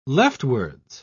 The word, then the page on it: leftwards: British English